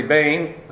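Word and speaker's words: Bane (בֵּן): Again, the word Ben, there, a son, actually Bane, it's actually pronounced with a Sarah there, Bane, and then Yehuda, the proper name, proper noun there, sons of Judah.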